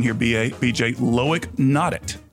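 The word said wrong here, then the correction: Loïc Nottet: No, Loic Note. – Loic Note. – It looks like Nate, but it's Loic Note.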